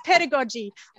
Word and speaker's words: pedagogies: Did I pronounce that pedagogies? It's always such a like tongue twister for me. Is that the right pronunciation? – It's not just a tongue twister for you. My husband always goes, what is that word? And it's pedagogy or digital pedagogies in my, case.